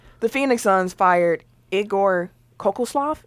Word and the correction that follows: Kokoskov: Is that how you say his name? Say it again. – Kokoslov. – Y'all keep saying it at the same time. Go ahead, Eric. – Kokoskov. – Kokoskov.